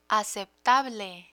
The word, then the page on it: aceptable: Spanish